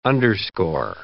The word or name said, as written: underscore